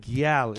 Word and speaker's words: Gyal: Island Gyal is the right way. Yeah. My man from Jamaica, when I went on vacation, said it's spelled G-Y-A-L, Gyal.